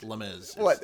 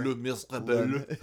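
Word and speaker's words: Les Misérables: So also, it's not pronounced Les Mis. – I don't know what it is. Yeah. Les Mis. Whatever. – Les Miserables. Les Miserables.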